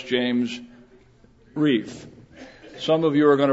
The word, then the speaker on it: Reff: And so I want to make a tie-in here because his name is James R-E-F-F. How would you pronounce that? No, his name is James Reef.